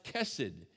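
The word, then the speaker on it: chesed: The Hebrew word there for kindness it's got a silent H on the front of it, but it's pronounced kesed.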